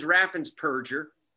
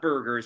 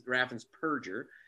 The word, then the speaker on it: Raffensperger: but scumbag, whose name is Raffensperger, not Berger, as people mispronounce it, really is Raffensperger, removed 198,000 people from the voter rolls illegally.